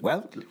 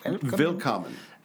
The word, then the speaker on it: Willkommen: So, Willkommen. Am I saying that? Am I pronouncing that correctly? – Willkommen. Willkommen.